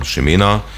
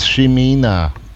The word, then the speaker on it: Ximena: Und da haben wir Ximena, spricht man so, obwohl es mit X geschrieben wird, und das ist die spanische Fassung. Also Schreibweise. – Jawohl, das ist ein spanischer Name, ein spanischer weiblicher Vorname. – Na, und also spricht man es jetzt wie? – Also ich singe es Ximena.